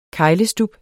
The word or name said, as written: keglestub